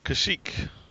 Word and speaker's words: Kashyyyk: What is the Wookiee's homeworld, and how do you pronounce it? – Ooh, Kashyyyk. – Exactly. You got it.